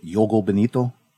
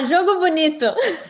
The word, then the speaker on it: Jogo Bonito: So the emphasis obviously was, how would you say it, Yoga Benito? – I'm sorry? – Yoga or Yoga Benito? How do I say it? The beautiful game? – Ah, Jogo Bonito.